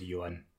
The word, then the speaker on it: Johan: But in France, we have that name actually, Johan, but we never pronounce H or the H in French, so that's why it's Johan.